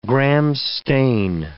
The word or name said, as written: Gram's stain